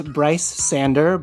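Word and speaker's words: Brice Sander: And I am at Bryce Sander, BRICESANDER. – Everywhere.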